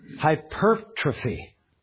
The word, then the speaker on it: hypertrophy: I was intrigued by this medical term, if I'm pronouncing it right, hypertrophy. For some reason it's hard. I want to say trophy, but it's on the per. Yeah. Hypertrophy, but it's spelled like hypertrophy.